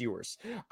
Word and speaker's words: Ewers: but Els, or however the fuck you say his name, that was Quinn Ewers. – I think it's Ewers.